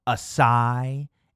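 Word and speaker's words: açaí: Well, if you really want to be the most pretentious living soul in the Whole Foods organic produce section, you need to say it right and with confidence. Acai. Say it with me now. Acai.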